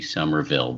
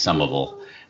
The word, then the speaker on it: Somerville: I think they pronounce it, you know, if you're speaking the King's English, it would be Somerville, but locally it's Somerville.